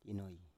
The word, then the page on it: inoi: Biak